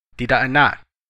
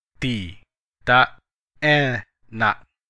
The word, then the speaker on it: tidak enak: In Indonesian, it's not delicious is tidak enak, tidak enak. Let's break it down by syllable. Tidak enak.